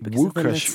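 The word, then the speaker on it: Łukasz: Fun fact, the L is actually pronounced as a W. – Lukasz Fabianski.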